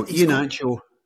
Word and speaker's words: Iheanacho: So he's got him, he's got Iheanacho, you know, like, no, like somebody says, people are pronouncing his name as if he's Iheanacho. As opposed to Iheanacho. Have I got that one right? I think I have. – No, Iheanacho.